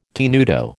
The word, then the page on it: tenuto: American English